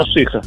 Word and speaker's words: Балашиха: А то тут спорят, Балашиха или Балашиха. – Даром. Ударение куда? – Балашиха. – Балашиха. Мне даже рассказывали, почему мы норовим сказать Балашиха. Потому что у одного певца популярного в России была песня такая, где он поет Балашиха. Поет неправильно.